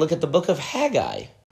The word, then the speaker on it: Haggai: Haggai, which is the correct pronunciation for this.